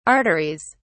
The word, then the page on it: arteries: British English